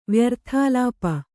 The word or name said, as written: ವ್ಯರ್ಥಾಳಾಪ